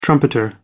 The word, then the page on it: trumpeter: American English